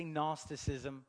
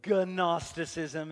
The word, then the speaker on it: Gnosticism: Say Gnosticism. Gnosticism. It has a G on the front, but the G is silent.